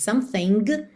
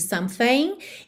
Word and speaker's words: something: A gente não diz something, mas sim something.